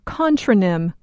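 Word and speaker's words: contranym: A word with opposing meanings is called a contronym, or contronym, spelled with an A.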